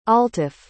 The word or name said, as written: altaf